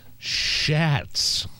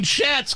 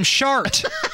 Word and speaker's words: Schatz: I mean, Schatz could throw you off a little bit. I mean, I understand maybe pronouncing Hirono wrong. – Nigel, she called him Shart.